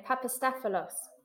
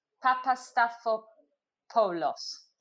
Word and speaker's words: Papastathopoulos: And then Papastathopoulos. – Oh, I love Greek name. Papastathopoulos. – No. Papastathopoulos.